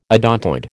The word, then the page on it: odontoid: American English